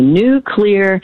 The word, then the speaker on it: nuclear: And then the third one that people mispronounce all the time is when they say nuclear, not correct. So if you, if it's cloudy today, and maybe, but tomorrow it's going to be a new clear day. Just say new clear and you will pronounce nuclear correctly.